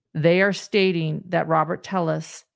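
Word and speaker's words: Telles: People in El Paso still use the Spanish pronunciation Tellis for the family name. But during his time in Las Vegas, Robert Tellis pronounced his last name Tellis.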